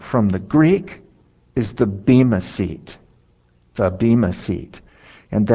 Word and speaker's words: bema: And if you look up the Greek word that's used here, it's bema. And it's B-E-M-A with a long line over the E. So that's what it looks like when it's pronounced in the Greek.